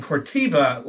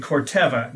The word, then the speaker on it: Corteva: I've also been made aware that I was mispronouncing Phytogen's new corporate parent company name, as I was saying Corteva last time on the podcast. It's actually Corteva, new name out there, and as per usual, I butcher my first attempt to get that right.